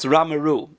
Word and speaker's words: Ramerupt: It's spelled Ramerupt, but if I'm correct, the UPT is just pronounced as Ru, so it's Ramaru in France.